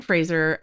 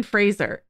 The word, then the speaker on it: Fraser: I'm Brendan Fraser, it's Fraser, not Fraser, get it right, you know.